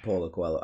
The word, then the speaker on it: Paulo Coelho: Paulo Coelho, I can't never say his name. – Paulo Coelho.